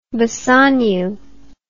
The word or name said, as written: Bassanio